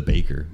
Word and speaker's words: Bejkr: So you don't pronounce the J. – It's just the Bejkr.